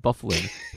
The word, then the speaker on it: Byfuglien: Dustin Byfuglien, that his last name is pronounced Byfuglien. His name is spelled Byfuglien. It's Byfuglien.